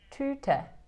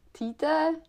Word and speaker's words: Tüte: Jo, já myslím, že jsi udělala velký pokrok, protože jestli si matně vzpomínám, tak si to říkala týte, týte.